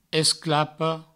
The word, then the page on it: esclapa: esclapo; provençal